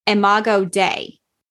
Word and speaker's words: imago Dei: The Latin word used here is imago Dei. Did I say that right? Imago Dei, right?